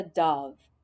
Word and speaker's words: dove: Saya wrote about a beautiful bird called a dove. Dove rhymes with love.